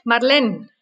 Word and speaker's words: Marlene: Marlene. – Entonces, ¿qué tipo de nombre es? – Es francés, Marlene.